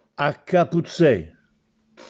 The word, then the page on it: Accapuzzè: Castellanetano